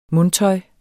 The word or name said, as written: mundtøj